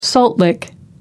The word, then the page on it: salt lick: American English